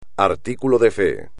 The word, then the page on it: artículo de fe: espagnol